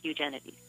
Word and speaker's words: Eugenides: Well, what I would say is that's how I pronounce Eugenides.